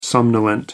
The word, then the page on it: somnolent: American English